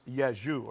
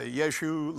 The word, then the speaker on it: Rzeszów: I know it's spelled weird. R-Z-E-S-Z-O-W, but it's pronounced Rzeszów, or at least that's my pronunciation, right?